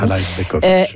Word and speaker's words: Alajbegovic: Puis là, ben, j'essaye un petit peu plus là, ce joueur de la Bosnie-Herzégovine, Kerim Alajbegovic. Je ne suis pas certaine si le premier J se prononce, mais d'habitude, les probablement comme un Y, Alaï.